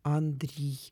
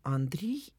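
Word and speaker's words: Андрий: Андрей, это та же самая история, что с Сергеем и Сергием. То есть, если мы говорим о традиционном книжном церковно-славянском произношении, то мы не можем посчитать это как Андрей. Он был бы тогда Андрей, что мы имеем в русском языке. А так как у Гоголя это имя пишется через «и», то значит и ударение будет Андрей, так же как Сергей, иначе был бы Сергей.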